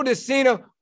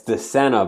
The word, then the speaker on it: De Sena: So Joe De Sena, welcome to the show. – Thanks for having me. It's De Sena, by the way, not De Sena.